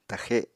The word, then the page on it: taxe7: apatani